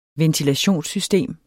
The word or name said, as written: ventilationssystem